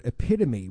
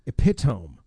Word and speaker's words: epitome: At 42, I learned that the written word epitome was not pronounced epitome, but it was pronounced epitome. I've used that word literally 10,000 times and said it wrong.